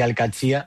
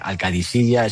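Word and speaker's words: Al-Qadsiah: Bueno, se suele decir Al-Qadsi, pero también hay gente que lo dice Al-Qadisiya. Es que es difícil, ¿eh? Ah. No es fácil, pero Al-Qadsi es lo más fácil.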